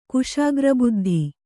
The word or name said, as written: ಕುಶಾಗ್ರಬುದ್ಧಿ